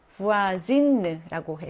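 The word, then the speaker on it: voisine: 부아진느라고 해서 얘도 발음도 바뀝니다.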